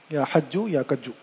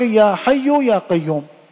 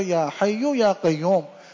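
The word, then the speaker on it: یا حی یا قیوم: تو اس کو سمجھایا بتایا کہ بھائی یا حجو کجو نہیں ہوتا, یہ ہوتا یا حیو یا قیوم.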